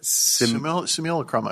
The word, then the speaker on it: simulacrum: Simulacrum, I believe.